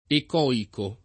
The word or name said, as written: ecoico